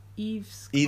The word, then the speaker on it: Yves Klein: Yves Klein? – Yves Klein.